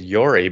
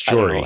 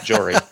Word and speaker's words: Jory: And her first name, I said Yori because I thought the J was pronounced with a Y. I don't know. – But it's Jory. – I just butchered it. And she said, oh, no, that one sounds just like it's spelled, Jory.